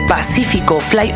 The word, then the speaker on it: Pacífico Fly: Oigan, hoy estoy muy contenta porque están estrenando material mis amigos de Pacífico Fly. Oigan, ¿así se pronuncia bien? Claro.